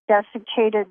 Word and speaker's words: desiccated: So they have dislocated liver. – Desiccated.